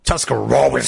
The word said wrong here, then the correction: Tuscarawas: It's Tuscarawas.